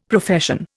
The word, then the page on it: Profession: Indian